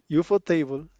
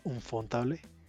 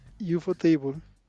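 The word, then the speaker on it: ufotable: Encima no lo podía pronunciar, mm. decía un fontable. Entonces, UFO Table eh,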